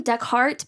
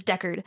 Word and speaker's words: Deckard: I call him Deckhart, but that's not his name because I know it just annoys my boyfriend. It's Deckard. So sorry if I mispronounce it.